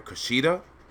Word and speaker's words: Kushida: And this match was, I don't know if I'm saying his name right, Kushida? – Yeah.